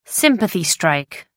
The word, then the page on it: sympathy strike: American English